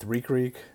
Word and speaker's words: Ric Ric: And I am super excited to bring you my interview with Tariq Sykes from Barbecue with Reek Reek. It's spelled BBQ with R-I-C-R-I-C, Barbecue with Reek Reek on all the social media, YouTube as well.